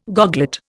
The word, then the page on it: goglet: Indian